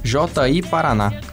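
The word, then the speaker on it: Ji-Paraná: Em 93, Internacional 9 a 1 sobre o Gi Paraná. JI Paraná, não sei como é que fala isso quem? JI Paraná.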